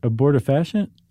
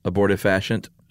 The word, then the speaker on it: abortifacient: What they call in, do you know how to pronounce that? – Abortifacient. – Abortifacient. – I think so. – Yeah, I think that's right.